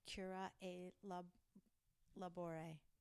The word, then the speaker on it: Cura e Labore: This is in the section Cura e Labore. Did I say that correctly? – Yeah.